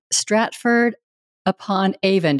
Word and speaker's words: Stratford-upon-Avon: Geoff is with us today from Stratford-upon-Avon. Did I pronounce that right? – You did.